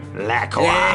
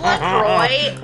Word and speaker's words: LaCroix: Please pronounce it LaCroix. – LaCroix. It's LaCroix.